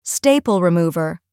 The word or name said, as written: staple remover